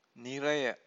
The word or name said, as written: niraya